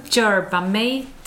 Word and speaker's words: yerba mate: Yerba mate. – Ja, just det. – Yerba mate. Yerba mate. – Faktaruta. Det uttalas lite försvenskat, yerba mate, och är som bekant en av professor Stigs favoritdrycker.